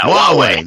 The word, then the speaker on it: Huawei: Huawei, there we go.